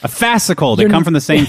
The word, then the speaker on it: fascicle: Starts with an F and rhymes with fascicle. – Fascicle? – That's it. Yeah, one fascicle.